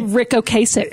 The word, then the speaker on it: Ocasek: Rick Ocasek.